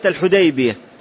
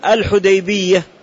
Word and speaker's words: الحديبية: والحديبية قد تنطق بالتشديد وقد تنطق بالتخفيف، وأهل الحديث وأهل الحجاز ينطقونها بالتشديد، والبلد في بلدهم. والروايتان صحيحتان عند أهل العلم، وقد صحح الأصمعي وتبعه الخطابي على تصحيح رواية التخفيف وهي رواية الحديبية، والأكثر كما سلف يقولون الحديبية،